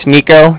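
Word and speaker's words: Niko: All right, yeah, like I said, my name's Nico. That's N-I-K-O. Calling from the Bank of Cardiff. And that's spelled C-A-R-D-I-F-F, as in Frank, Frank.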